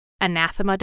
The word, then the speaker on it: Anathema: Oh, her, it's pronounced anathema, but it's spelled anathema.